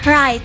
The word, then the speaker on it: write: Jadi huruf W-nya itu silent. – Oh, silent. – Jadi dibacanya write. – Write. – Yeah. Right. Right. Kalau kita itu write. Right. W-nya tuh nggak kedengeran.